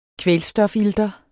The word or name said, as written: kvælstofilter